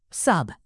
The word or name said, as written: sub